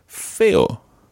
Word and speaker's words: feo: Now, the opposite of guapo, guapa, is feo. – Feo. – That's spelled F-E-O.